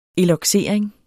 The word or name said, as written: eloxering